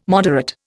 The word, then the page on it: moderate: Indian